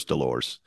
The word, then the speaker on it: Delors: And the headline summarizing Thatcher's speech read, Up Yours Delors, which of course has a silent S at the end of it. So it looks like Up Yours Delors.